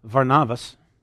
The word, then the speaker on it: Varnavas: Now, a little side note about Barnabas, or in the Greek it's actually pronounced Varnavas.